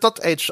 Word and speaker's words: Dotage: Genau, ja, er wird Dottage ausgesprochen, das verrät uns das Spiel freundlicherweise. Sogar selbst, indem es den Titel quasi vorliest im Hauptmenü.